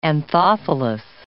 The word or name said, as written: anthophilous